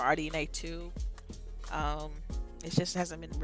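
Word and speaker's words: RDNA 2: Why does Sony get a pass for not officially disclosing anything like uh like RDNA or RDNA two. Did I say that right? Yeah, you said it right.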